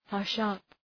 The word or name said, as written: hush up